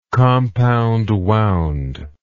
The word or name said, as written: compound winding